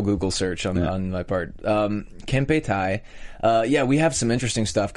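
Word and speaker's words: Kempeitai: I thought it was Cappy Tai at first, and I was like, that doesn't sound Japanese.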